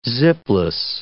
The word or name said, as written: zipless